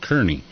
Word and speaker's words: Cerney: Chris Kearney, C-E-R-N-E-Y. So it looks like Cerny, but I guess it's pronounced Kearney. Chris Kearney, C-E-R-N-E-Y.